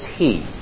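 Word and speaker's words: he (ἡ): The definite article in the Greek for the is he. A long E with an aspirate.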